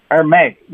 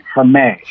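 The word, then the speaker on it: Hermès: Hermé, you can barely pronounce the H, and then it's like a French ending. Hermé. – Hermé.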